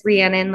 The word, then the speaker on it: Rhiannon: As you said, my full name is Rhiannon, like the Fleetwood Mac song. But most people can't pronounce that.